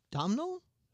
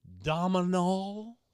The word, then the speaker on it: Domhnall: D-O-M-H-N-A-L-L. – Domnall? – Dominal, like dominoes, but dominal.